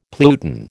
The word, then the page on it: pluton: American English